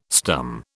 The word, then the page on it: stum: British English